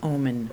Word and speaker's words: Ooman: Actually, it's pronounced like the word. It's pronounced Ooman.